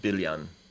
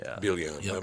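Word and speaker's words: Biljan: Like our name would be pronounced Bilyan. – Bilyan. – Yeah, the J is a Y. – Bilyan.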